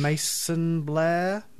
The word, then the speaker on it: Macon Blair: Macon Blair, M- Blair? – Yep, yep, you nailed it.